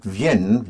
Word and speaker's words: Vienne: And for their coming, you have the E-N-T, but it's spelled V-I-E-N-N-E-N-T, which is pronounced Vienne. – Vienne. – Yes. It so happens that Vienne, their coming, has the same sound as in French, the city of Vienna is called Vienne. – Vienne. – V-I-E-N-N-E.